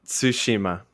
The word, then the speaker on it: Tsushima: You're the Japanese major. Please pronounce that. – Well, Tsushima. Tsushima is how you would say it. – I feel like I'm like slurring it though. Like, I feel like I'm adding an H in there.